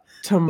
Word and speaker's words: Tamara: Her name is spelled T-A-M-A-R-A. So I think in my head going Tamara. – Tamara. – But I was watching this interview last night, and then you sent me an interview, and they keep saying Tamara. So let's just go with Tamara, the A. – I guess Tamara. Yeah, the A is silent.